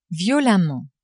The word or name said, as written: violemment